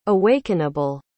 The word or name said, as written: awakenable